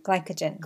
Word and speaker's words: glycogen: It was the body stores pasta as glycogen? – Glycogen. – Glycogen. And glycogen attracts water. So when you eat pasta, it stores it as the glycogen, and the glycogen attracts water, which means you're going to be up on that scale.